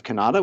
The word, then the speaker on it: Cannata: I'm at Jeff Cannata, which is spelled with two N's and one T.